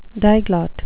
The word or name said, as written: diglot